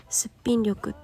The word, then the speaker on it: 素力: 筋からと書いてすっぴん力って読もう